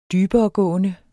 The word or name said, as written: dyberegående